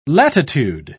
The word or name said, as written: latitude